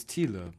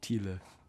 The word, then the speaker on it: Thiele: Is it not Thiel? Is it Thiel? – It's Thiele, but everyone says Thiel. – Okay, well, I think the other way sounds cooler. How do you say it again? – Thiele.